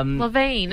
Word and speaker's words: Lavigne: Levine. Who's Avril Levine? It is spelt Lavigne, though.